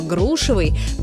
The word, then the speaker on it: грушевый: Но вернемся к грушам и запомним, что ударение во всех формах прилагательного «грушевый» падает на первый слог, как в слове «груша».